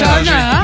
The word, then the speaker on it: Horner: Yvette, comment tu prononces toi? C'est Yvette Horner. – Yvette Horner. – Il y a le H. Bien joué. Aspiré.